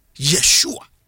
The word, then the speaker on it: Yeshua: omo pronounce no ye Yeshua Yeshua